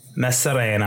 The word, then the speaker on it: Maçorano: Maçorano.